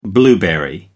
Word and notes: blueberry: American English

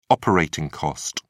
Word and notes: operating cost: British English

